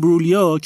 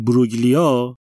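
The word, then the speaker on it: Imbruglia: He's not این برولیا که ما اون موقع بلد نبودیم جیه توی اسمش رو هم تلفظ میکردیم میگفتیم این بروگلیا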